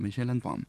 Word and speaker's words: Michel Antoine: Es así como se pronuncia, Michel Antoine.